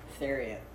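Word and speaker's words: Thieriot: I'm like, how you pronounce it? – Theriot.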